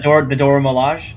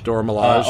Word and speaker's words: Dora Milaje: I was kind of hoping for a, I can never pronounce her name, Dajora Milana Warriors. – Oh, the Dora Milaje? – Dora Milaje.